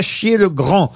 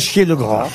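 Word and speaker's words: Achiet-le-Grand: Pour Christine Domiti, qui habite à Chier-le-Grand. Dans le Pas-de-Calais, à Chier-le-Grand. Ça s'écrit à Chier, c'est à Chier-E-T.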